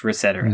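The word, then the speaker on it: ResetEra: He said it on video, and he's like, yes, it's pronounced Reset Era.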